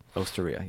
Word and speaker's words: Osteria: Well, your restaurant, your Osteria, that's how you say it, right? – Osteria, yes.